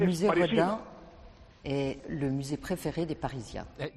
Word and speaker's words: Rodin: Museo Rodin. Bueno, Daniel sabe francés, es como se pronuncia en francés.